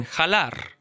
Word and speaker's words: jalar: La jota makes the jota sound, like in jalar.